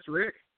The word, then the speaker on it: Rik: MonsterCast, BTAT, that's MonsterCast, then BTAT, or MonsterCast Rick, R-I-K, don't spell my name wrong, please.